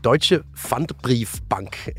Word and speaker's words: Deutsche Pfandbriefbank: Det hedder Deutsche Pfandbriefbank. Kan du ikke lige prøve at forklare, hvad det er, og måske lige give en bedre udtale af det? – Jo, altså Deutsche Pfandbriefbank er en tysk bank, som er kommet i problemer.